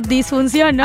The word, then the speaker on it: disfunción: Ándale, le pones horqueta tal cual para que ahí de una vez no tenga ahí su disfunción o difunción, ¿cómo se dice? – Su disfunción, ¿no? – Ándale.